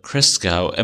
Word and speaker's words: Christgau: One more point, but my proto-PC music justification, famed a music critic, Robert Christgau, am I saying that correctly? – That's how I've always pronounced it.